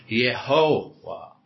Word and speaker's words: Yehovah: And it was meant to be pronounced as a Y. So it was Yehovah. In fact, even the letter W is also a later addition to the alphabet. So it was Yehovah, which now is known as Jehovah, right?